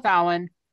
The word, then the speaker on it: Samhain: Like Halloween, is based on the Celtic festival Samhain. If I said that wrong, don't come for me, people. – Samhain. – Samhain, sorry, Samhain. – Samhain.